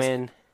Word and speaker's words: Nguyen: Nguyen.